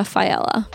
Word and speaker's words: Failla: So without further ado, here is Maria Fiella.